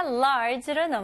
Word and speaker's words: large: 제대로 영어로 발음하실 거면 large, 이름절로 하셔야 돼요. 오케이, 이거는 많은 연습 통해서 가능합니다. 오케이, large 아니고요. large. large. 그리고 마지막으로 J 발음으로 끝내셔야 돼요. 그래서 모음 소리 없이 large.